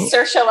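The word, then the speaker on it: Saoirse: we say Sersha like inertia.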